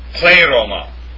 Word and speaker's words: pleroma: The major Greek form for fullness in the New Testament is pleroma. Pleroma, that's how it's pronounced.